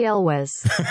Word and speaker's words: Elwes: Cary Elwes.